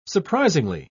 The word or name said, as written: surprisingly